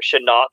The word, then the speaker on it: Shenault: Anyhow, I'm going to go with Laviska Shenault here, or Shenault. I'm sorry, I watched them enough this year to finally pronounce it correctly.